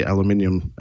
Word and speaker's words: aluminium: But that's the aluminium, and I'm going to say aluminium with stress on the eye there.